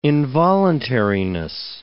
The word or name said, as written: involuntariness